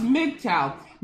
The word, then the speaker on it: MGTOW: And one radical fringe that is growing is MGTOW, which stands for Men Going Their Own Way, and pronounced MGTOW.